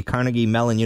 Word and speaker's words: Carnegie Mellon: New study out of the Carnegie Mellon University, is that how you say it? – Uh-huh.